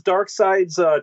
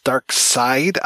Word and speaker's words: Darkseid: I never knew that it was actually pronounced Darkseid. I thought it was Darkseid. So I always thought it was like West Side kind of thing.